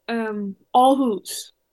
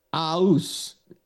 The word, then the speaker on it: Aarhus: You don't say the R. – Oh. How do you say it? – Give like a little like Aarhus. – Aarhus. No R. – Well, there's an R, but we don't pronounce it like Americans do. – No. – It's like the V in my name. You like the, it's there, but you don't really say it like most people in America would think you do.